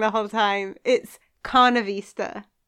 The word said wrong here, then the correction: Carvanista: No, it's Carvanista. Sorry, it's Carvanista. – I don't know which way around. – It's not like carnivore.